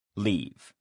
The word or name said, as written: leave